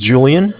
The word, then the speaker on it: Julian: Okay, my name is Julian. It's J-U-L-I-A-N. – Mm-hmm. – Calling from Bank of Cardiff. Cardiff is spelled C-A-R.